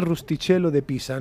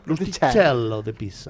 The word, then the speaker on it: Rustichello de Pisa: Así es, no es otro que Rustichello de Pisa. Perdóname, si lo he pronunciado mal. – Ha ido muy bien, ¿eh? Con, un, tono muy chulo. Muy ¿eh? Rustichello de Pisa.